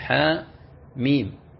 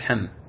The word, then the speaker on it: حم: فتقول حاء ميم، حاء ميم تنطق حاء وحدها ثم ميم وحدها، ولا تقول حم.